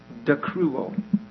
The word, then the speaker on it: dakruo: It's dakruo, D-A-K-R-U long O. Dakruo.